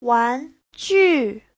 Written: wan2ju4

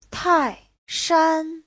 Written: tai4shan1

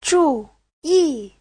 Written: zhu4yi4

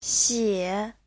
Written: xie3

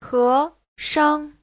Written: he2shang5

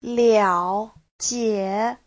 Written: liao3jie3

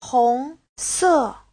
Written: hong2se4

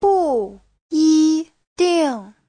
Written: bu4yi1ding4

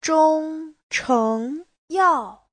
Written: zhong1cheng2yao4

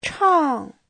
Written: chang4

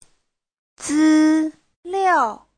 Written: zi1liao4

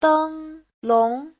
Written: deng1long2

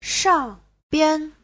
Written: shang4bian5

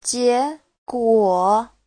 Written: jie2guo3

